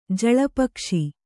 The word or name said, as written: ಜಳಪಕ್ಷಿ